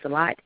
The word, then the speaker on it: Lott: If you can't find that, you can definitely contact me on my page, which is Val, V-A-L, last name is Lott, L-O-T-T, and I'll be happy to direct you and send you a link, and you can go from there.